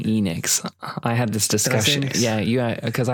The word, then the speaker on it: Enix: I think it's an American thing. I find that Americans tend to change the pronunciation of certain words, and Enix stuck here, but it is Enix. That's how the Japanese say.